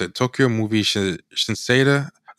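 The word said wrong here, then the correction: Shinsha: Let me get the name right. It's Tokyo Movie Shinsa.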